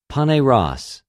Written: πονηρος

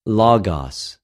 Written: λογος

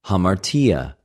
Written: ἁμαρτια

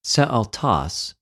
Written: σεαυτος

